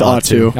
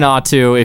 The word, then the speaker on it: ATO: I want to do A-T-O. Right by the guy. If I'm going to make fun of his British accent, I need to at least pronounce his name correctly. – Yeah, it's Atu. – Okay. I'm trying Atu if you're listening to this.